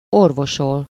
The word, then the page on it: remedy: American English; /ˈɹɛm.ə.di/